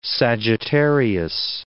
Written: Sagittarius

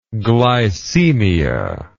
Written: glycaemic

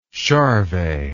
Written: Charvet

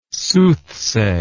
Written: soothsay

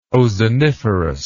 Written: ozoniferous

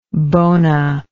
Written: bona

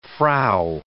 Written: Frau

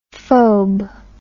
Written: phobe

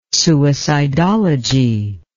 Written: suicidology

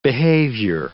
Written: behavior

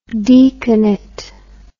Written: deaconate

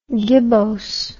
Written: gibboseness

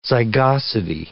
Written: zygosity

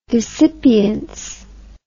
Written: desipience

